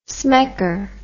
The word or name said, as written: smecker